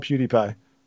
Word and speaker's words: PewDiePie: PewDiePie. – PewDiePie. – Really, that's how you say it? – PewDiePie.